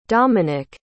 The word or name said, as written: domenic